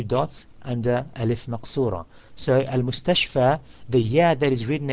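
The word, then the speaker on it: al-mustashfa (المستشفى): So the word is al-mustashfa and it finishes with a alif maqsura, meaning it finishes with a long vowel of fatha, long fatha vowel sound of a, and it does not take the kasra.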